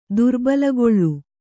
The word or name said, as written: ದುರ್ಬಲಗೊಳ್